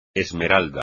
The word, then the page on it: esmeralda: espagnol